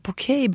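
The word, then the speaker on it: Poké: Ja, Porkey Bowls. – Du ja. är den enda i Sverige som uttalar det rätt.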